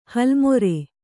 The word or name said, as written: ಹಲ್ಲುಮುರಿ